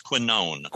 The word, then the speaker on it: quinone: and it's changed to the 6-PPD quinine is what's in tonic water, right? So it's not that, yeah. Probably. – I think it's pronounced quinone. – Quinone, thank you.